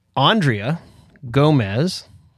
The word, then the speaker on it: Aundrea Gomez: So our guest, properly pronounced Aundrea Gomez, is, you know, and I always forget your title before I go into the organization, but are you grassroots director in Tennessee? – Tennessee director, yeah.